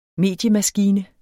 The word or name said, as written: mediemaskine